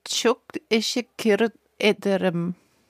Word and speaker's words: çok teşekkür ederim: Also çok teşekkür ederim. – Ja, das war gut.